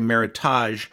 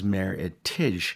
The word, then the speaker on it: Meritage: Some people say Meritage, but I believe the exact pronunciation is Meritage, and it was instituted in 1989.